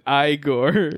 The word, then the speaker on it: Igor: In Young Frankenstein, the guy's name is Igor, but he pronounces it Igor. – Oh. – And he, like, corrects them on it.